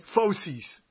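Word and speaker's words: fauces: You gave me this, how do you pronounce this, Fosses? – Foces. – As opposed to feces.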